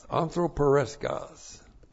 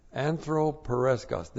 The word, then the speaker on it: anthropareskos: And then there is one that's used in a negative way in the Word of God, and that's anthropareskos. This is A-N-T-H-R, long O, P, that's, you see, anthropos, and then A, R, E, S, K, O, S. Anthropereskos.